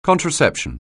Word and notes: contraception: anglais

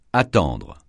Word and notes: attendre: francais

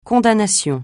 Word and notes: condamnation: francais

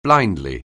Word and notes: blindly: anglais

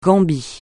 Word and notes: Gambie: francais

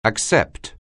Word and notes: accept: anglais

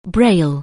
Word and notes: braille: anglais

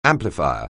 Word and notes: amplifier: anglais

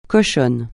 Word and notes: cochonne: francais

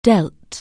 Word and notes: deal: anglais

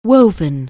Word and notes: weave: anglais

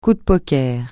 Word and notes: coup de poker: French

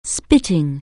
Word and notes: spit: anglais

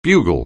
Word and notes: bugle: anglais

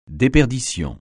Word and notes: déperdition: francais

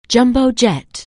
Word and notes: jumbo jet: anglais

